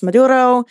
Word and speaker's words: Maduro: So he went on an extended kind of criticism of Venezuela, of Nicolas Maduro, you know, the president of Venezuela.